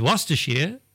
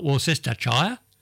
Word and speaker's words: Worcestershire: Worcestershire is Worcestershire, not Worcestershire, as I say.